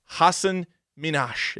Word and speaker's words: Hasan Minhaj: Hasan Minhaj? – Hasan Minhaj is his name.